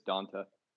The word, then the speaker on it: Donta: I'm fairly certain that it's Donta. – Okay. Well, because it's spelled the same way as Donta Hightower, I believe. And that's Donta.